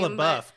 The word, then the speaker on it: Shia LaBeouf: Shia LaBeouf, come on.